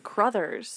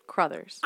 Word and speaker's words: Crothers: It's just Carruthers. – What did I get Carruthers? – Scatman Carruthers. – It's Carruthers. – I prefer Carruthers. – You're adding an extra syllable between the C and the R. Crothers.